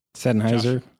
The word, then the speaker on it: Sennheiser: And I use some Sennheiser. Did I say that right? – Sennheiser.